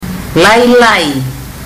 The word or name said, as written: lailai